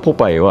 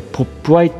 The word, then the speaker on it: Popeye: 要するにまあねそのナンバー 18 漢字の読めない弟ですよ、うん、ポパイっていうふうに読めなかったんだね、うん、スペルが、うん、普通にまあポパイは POPEYE、